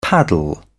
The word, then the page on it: paddle: British English